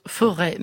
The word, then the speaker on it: Forez: Alors je me fais taper sur les doigts par une auditrice, Rémi Knafou, parce que je vais parler des monts du Forez. Or ça se prononce Forêt, mais à culpa. Le géographe me donne la réponse. Effectivement, on ne prononce pas le Z.